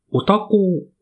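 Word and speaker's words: お多幸: お店の名前が、おたこう本店。おたこうっていうのは、ひらがなのおに、多い少ないのお、多いに、幸せと書いておたこうと読むと思うんですけど、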